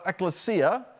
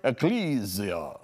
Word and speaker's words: ecclesia: I always tell people, it sounds like a vampire word if it's pronounced right. It's not ecclesia. I've often called it ecclesia. I'm glad to see you, ecclesia, but it's actually ecclesia.